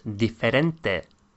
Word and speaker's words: diferente: Different becomes diferente.